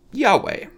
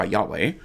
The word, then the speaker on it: Yahweh: Typically, in English, we would say that that is pronounced probably Yahweh.